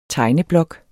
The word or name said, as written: tegneblok